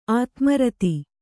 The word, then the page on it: ಆದಿಮೂರುತಿ: ādimūruti